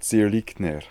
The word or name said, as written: Zirlikner